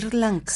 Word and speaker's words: Sri Lanka: Sri Lanka.